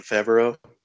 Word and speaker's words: Favreau: John Favreau. – Favreau.